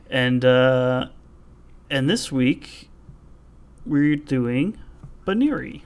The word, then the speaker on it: Buneary: So, Buniri, B-U-N-E-A-R-Y, clearly an ear thing going on, Buniri, I just wasn't quite sure how to pronounce that. – Yeah, I was listening a little bit, and it's, yeah, Buniri, which is a bit of an odd name.